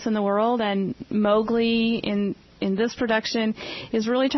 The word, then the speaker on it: Mowgli: He plays the role of Mowgli.